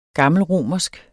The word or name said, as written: gammelromersk